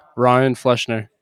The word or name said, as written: Fleschner